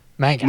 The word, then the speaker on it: manga: I was waiting for somebody to say Magna. – Magna? Manga. – Manga, although I think most Americans pronounce it manga or something.